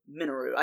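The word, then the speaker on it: Mineru: Minoru, I think is how they pronounce it through the cutscenes.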